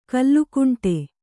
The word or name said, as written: ಕಲುಗುಂಡು